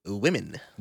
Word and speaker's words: women: Imagine it's spelled W-I-M-M-E-N. Say it like that. – Women. – Yes, women. But you're saying woman, as in women.